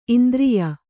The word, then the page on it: ಇಂದ್ರಿಯ: Kannada